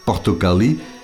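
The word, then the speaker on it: portokali (πορτοκάλι): En grec, portokali, appuyé sur la dernière syllabe, est la couleur orange, et appuyé sur l'avant-dernière syllabe, l'orange, le fruit.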